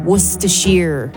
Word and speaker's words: Worcestershire: Worcestershire is how you pronounce it.